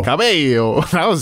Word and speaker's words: Cabello: They have multiple L's. – Right. – But they're pronounced differently. – Confusing. You can understand my confusion. – Yeah, I get it. – Camila Cabello. – Cabello.